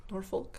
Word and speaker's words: Norfolk: How do you pronounce it? Norfolk? Do you pronounce the L? Norfolk? – Not if you're a native.